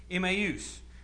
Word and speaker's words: Emmaus: There's two disciples that are walking the 7-mile stretch from Jerusalem to a small village called Emmaus. And that's the right pronunciation. I looked it up. That's what Strong's definition is. Emmaus.